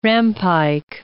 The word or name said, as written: rampike